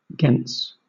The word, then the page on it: gens: British English